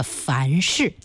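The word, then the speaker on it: 繁峙: 好，好多朋友都答对了，那滹沱河发源地山西的樊氏啊，它是这样的一个读音。 – 对啊，它不是质啊，不是对质的质。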